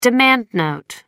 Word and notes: demand note: American English

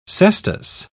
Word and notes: cestus: British English

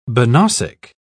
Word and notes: banausic: British English

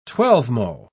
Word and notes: twelvemo: British English

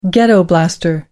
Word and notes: ghetto blaster: American English

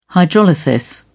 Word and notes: hydrolysis: British English

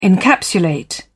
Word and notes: encapsulate: British English

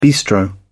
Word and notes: bistro: British English

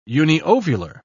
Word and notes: uniovular: British English

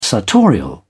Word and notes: sartorial: British English

